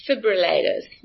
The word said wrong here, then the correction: defibrillators: I can never pronounce that word. – Defibrillators. – Did I say it right? That's it. Defibrillator.